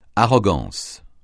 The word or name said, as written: arrogance